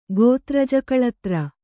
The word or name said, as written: ಗೋತ್ರಜಾಕಳತ್ರ